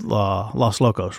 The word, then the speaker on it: Los Locos: Am I pronouncing it right? – That is Los okay. – Locos. – Yep. Okay, Los Locos. – Yep, there you go. – I didn't put the right pronunciation on Los.